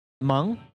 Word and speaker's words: Meng: Try and get some Ming from Huawei on the show to teach Huawei. Meng. Okay. Sabrina Meng from Huawei. Now, I'm not 100% sure who Sabrina Meng is, but I'm guessing...